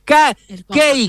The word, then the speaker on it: Cake: Caque. – A ver, para que se lo muestre. – Muéstrame el compa. Cake. Claro. La banda se llama Cake y la banda estadounidense Cake. Se escribe C-A-K-E.